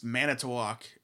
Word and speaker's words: Manitowoc: And for those that, it's Manitowoc is how it's pronounced.